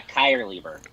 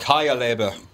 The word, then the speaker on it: Keierleber: Yeah, Keierleber.